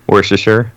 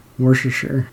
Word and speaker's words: Worcestershire: How do you say it? – Worcestershire. – Worcestershire.